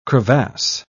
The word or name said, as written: crevasse